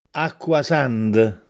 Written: Acquasànde

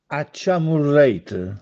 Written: Acciamurrète

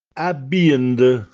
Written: Abbjìnde

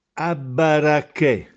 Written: Abbarracchè

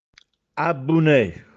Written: Abbunè

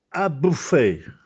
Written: Abbuffè

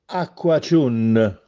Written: Acquaciùnne

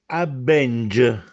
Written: Abbenghiarse

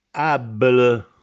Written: Abbele

